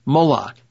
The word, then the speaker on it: Moloch: The names Maul and Locke, always spoken of in that order, are pronounced the same as the name Moloch,